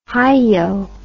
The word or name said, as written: hiyo